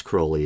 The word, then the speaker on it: Crowley: And yes, Crowley is the correct pronunciation.